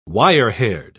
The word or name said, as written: wirehaired